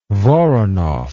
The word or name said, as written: Voronoff